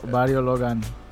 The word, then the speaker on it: Barrio Logan: Did I say it right? – Yeah. – Barrio? – Uh-huh, Barrio Logan. – Logan? Okay, I didn't say Gan, but Logan.